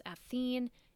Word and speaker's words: Athena: Athena was also sometimes pronounced as Athene,